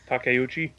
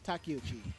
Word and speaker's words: Takeuchi: Takeuchi? – Yes, Naoko Takeuchi.